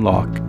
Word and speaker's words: Lok: Learn everything you can copywriting from, you know, Dan Locke, L-O-K. Dan Locke, that's how you pronounce his last name. Spell his last name. It's L-O-K as in Locke, without the C.